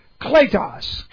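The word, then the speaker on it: Kletos (κλητός): Kletos, K-L, a long E, T-O-S.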